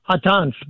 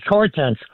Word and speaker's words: Hortense: In French, it's pronounced Hortense, but in English, it's Hortense.